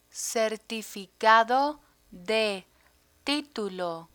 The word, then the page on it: certificado de titulo: spanish